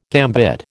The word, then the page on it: gambit: American English